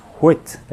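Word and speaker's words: hwæt: On pourrait dire huet, en fait, se prononce huet, avec un bon H devant, qui était traduit dans notre édition par orsa.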